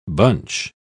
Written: bunch

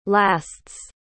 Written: lasts

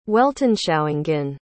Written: weltanschauungen

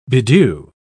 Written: bedew

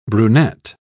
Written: brunet

